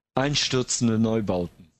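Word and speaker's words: Einstürzende Neubauten: Apollo 440, The Prodigy, Einstürzer Neubauten. I couldn't say that properly. If you just like to kind of inject. – Einstürzer Neubauten.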